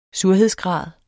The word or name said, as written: surhedsgrad